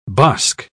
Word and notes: bosk: British English

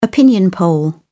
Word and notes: opinion poll: British English

